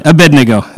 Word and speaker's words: Abednego: Anyway, Abednego is how you pronounce that.